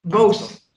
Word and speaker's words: Boese: And today I am joined by Franz Boise. – Boese. – Sorry. You are German, so you did give me the German pronunciation as well, but I can't quite wrap my tongue around that one.